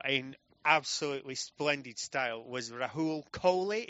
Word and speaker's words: Rahul Kohli: That is correct. You pronounced it wrong on stage. – I did. – You said Rahul Kohli, and I was like, that's not my fucking name.